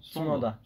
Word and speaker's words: Tsunoda: Tsunoda.